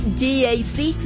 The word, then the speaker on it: GAC: No se dice GAC, se dice GAC nuevamente. GAC. Y se maneja mejor de lo que se pronuncia.